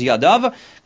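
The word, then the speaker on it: yadav (יָדָיו): It says shtei yadav, even though it's written as yado, it's pronounced as yadav.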